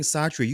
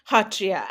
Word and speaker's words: Sattriya: I've been saying Satria. You said Hatria. – Hatria. And we write it as Satria. You know, the way to pronounce it in the Assamese way is Hatria. The sa is silent.